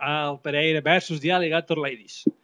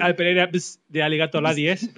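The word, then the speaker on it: Al Pereira vs. the Alligator Ladies: Se ha de pronunciar así, ¿no? Si no sirve. – Sí, no sirve. Sí, se puede decir Al Pereira de Alligator Ladies, pero no queda bien.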